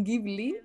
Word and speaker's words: Ghibli: arroba Alice in Ghibli, eh, em, Alice se escribe Alice con C, in, así tal cual, y Ghibli, eh, como los estudios Ghibli, G-H-I-B-L-I.